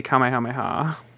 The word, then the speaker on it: Kamehameha: Say Kamehameha. – But you have to say it right, which is like dragging out.